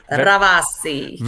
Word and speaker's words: Ravasi: I've got to roll my R right. Ravasi. Am I saying that right? I got to get your R. I wish I could get it like you say it.